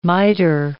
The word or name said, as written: miter